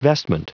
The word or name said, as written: vestment